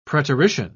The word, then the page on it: preterition: British English